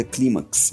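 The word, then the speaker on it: Climax: É, acho que é, pronuncia Clímax mesmo, assim.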